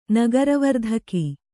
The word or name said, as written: ನಗರವರ್ಧಕಿ